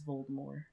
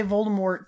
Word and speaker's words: Voldemort: Yeah, in the films they all say Voldemort, but in the audiobooks they say Voldemort. They drop the T.